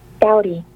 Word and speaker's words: doughty: It's spelled D-O-U-G-H-T-Y. So it looks like dough. So you put a T-Y on it, and the pronounce is totally different.